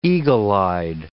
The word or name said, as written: eagle-eyed